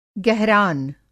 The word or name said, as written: गहरान